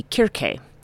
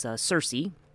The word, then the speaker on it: Kirke: And the subject of this week's talk is going to be Kirke, sometimes pronounced in sort of our Western parlance as Circe.